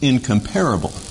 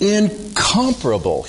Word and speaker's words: incomparable: Now, you might think that this word is pronounced incomparable. And many people do, and I shudder to myself when people say that, but it's incomparable. He's incomparable. There is none like him. Emphasis on the second syllable.